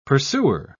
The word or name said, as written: pursuer